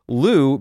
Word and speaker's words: Lugh: Their theory, well, not their theory, but what they put forward is millennia ago, Lu, L-U-G-H, but pronounced Lu, was the Celtic god of sun and light.